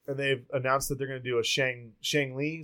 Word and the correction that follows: Shang-Chi: Is that how you say it? – Shang-Chi. – Shang-Chi.